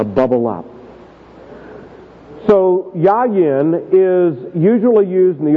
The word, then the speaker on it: yayin: The Hebrew word, the first word is the word yayin. It's spelled Y-A-Y-I-N. Yayin.